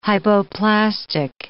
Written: hypoplastic

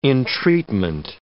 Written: entreatment

